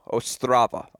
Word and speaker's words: Ostrava: And by the way, it's a hard O, Ostrava. My dear friend Gil Gross informed me I was pronouncing it incorrectly.